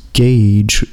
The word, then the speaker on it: Gaej: The artist name is Gage, which is spelled G-A-E-J.